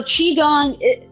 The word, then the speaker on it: Qigong: Is it Qigong? – Qigong, yes.